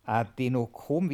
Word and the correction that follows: Adrenochrom: wie heißt das nochmal? – Adrenochrom.